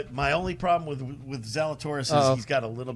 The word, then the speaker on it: Zalatoris: Will Zalatoris, yes.